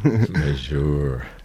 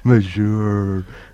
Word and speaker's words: Majeure: Majeure? How do you say this? – Majeure. – Majeure.